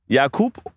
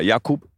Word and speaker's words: Jakub: Jakub und mit K und nicht mit C. Ja, Jakub.